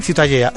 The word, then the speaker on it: Ciutadella: La Ciutadella.